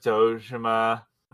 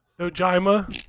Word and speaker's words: Dojima: I am the dragon of Dojima. – Listen, I should have pronounced it Dojima. – Dojima? – Dojima.